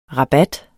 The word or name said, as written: rabat